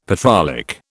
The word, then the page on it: petrolic: British English